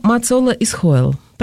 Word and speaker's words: Мацола Исхойл: А написала ее Мацола Исхойл. Правильно я делаю ударение, да? – Да, все верно.